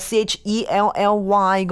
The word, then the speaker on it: Chelly: 咁但你话呢个 Canyon de Chelly，我成日睇地图佢系写 Canyon de Chelly 嘅，C H E L L Y 嘅，点解你会读 de Chelly 嘅？ – 佢读音应该系按照法文读嘅，所以就系冇咗嗰个嘅 Y 音。 – 咁所以如果大家睇地图嘅时候，你可能好难揾到 de Chelly，但如果你识法文，你就揾到啦。